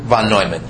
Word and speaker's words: von Neumann: Von Neumann, yes.